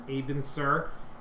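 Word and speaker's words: Abin Sur: Aben Sur.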